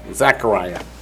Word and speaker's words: Zechariah: Did I say it wrong? Oh, Zechariah. Thank you, 12:10.